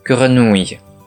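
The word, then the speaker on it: grenouille: 同じく、ういという発音を含む単語に、グルヌイがあります。カエルを表す単語ですね。綴りは、ジェ、エ、う、 N, O, U, I, L, L, E。続けて発音すると、グルヌイです。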